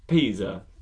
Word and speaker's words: Pisa: Pizza. – Yeah. Well, it's Pisa.